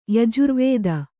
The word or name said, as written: ಯಜುರ್ವೇದ